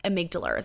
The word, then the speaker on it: amygdala: Amygdala, if you want to Google it, it's spelt like amygdala, is how it's spelled. Amygdala is how it's pronounced.